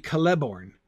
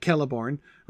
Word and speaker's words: Celeborn: I mean, technically, if the stress in elvish names is always on the second to last syllable, then it should be Celeborn. But that doesn't make a bit of sense. Again, the explosiveness of the hard C sound, of the k sound at the bit at the beginning, that's feels to me like it needs the stress, right? So I almost always, whenever I get especially like Celeborn, right, a three syllable name with the hard C at the beginning, I always do that.